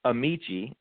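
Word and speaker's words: Amichia: We're going to start with Kofi Amasiya with the Packers. – Kofi, he's it's Amici is how you pronounce it, and he's actually gearing up for training.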